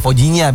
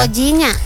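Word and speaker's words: Vozinha: Ada kiper dari Tanjung Verde namanya Fozinha. Bacanya Fojinya biasanya ya. – Fojinya.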